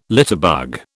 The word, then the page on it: litterbug: British English